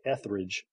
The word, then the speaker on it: Etheridge: I, wanted to you know demonstrate professionalism but with a last name like Etheridge um, second graders sometimes had a little trouble with with the pronunciation of that. – So mm. there was nothing more humbling than than kids mispronouncing your name, which made for some fun moments. So I got called everything from Mr. Estridge to Mr. Ostrich.